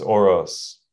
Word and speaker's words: Orosz: It's Orosz.